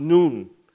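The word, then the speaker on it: Nun (ن): It's the letter N in Arabic, and it's pronounced Nun.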